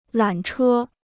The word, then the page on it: 缆车: lǎn chē